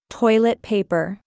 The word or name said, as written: Toilet paper